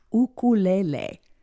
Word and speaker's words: ukulele: Now, according to Roy Sakuma, who's been teaching the instrument for more than 50 years, so he should know, it's pronounced ukulele.